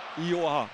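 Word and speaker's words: Iyoha: Ioha, so wird er, glaube ich, ausgesprochen.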